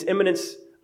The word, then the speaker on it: immanence: And it's immanence, I-M-M-A-N-E-N-C-E. I-M-M-I, immanence, if I could try and pronounce that I, is like coming soon, right?